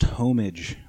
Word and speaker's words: homage: You know, like basically like giving homage to Nets of days past. – Pronounced homage. Homage.